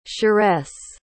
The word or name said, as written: cheresse